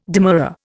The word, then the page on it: demurrer: Indian